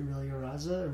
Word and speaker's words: Rilloraza: And follow me at Zeth Aureliaraza. Aureliaraza is spelled with an R. – His name is spelled Z-E-T-H.